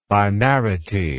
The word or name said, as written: binarity